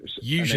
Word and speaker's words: Eugenie: At least you've got the name right. Heavy on the U, Eugenie. And then quick on the je, me. – Okay. Eugenie.